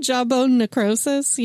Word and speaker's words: jawbone necrosis: That jaw broke, nash, and crawfish. How do you say that? – Jaw broke, and crawfish. Jawbone necrosis. Yeah.